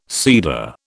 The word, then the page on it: cedar: British English